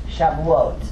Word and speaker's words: Shavuot: It's Shavuot, and it means weeks in Hebrew. Now, some of your Jewish friends might say, because they say to me, that's not how I pronounce it. And I said, coming from New York, I said, I know, you pronounce it Shavuos, because that's the Eastern European way. This is the Israeli way, how to say that word.